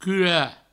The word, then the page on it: curar: cura